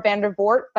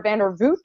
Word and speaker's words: Vandervoort: I always say Laura Vandervoort, but I think it's Laura Vandervoort. I always pronounce it wrong.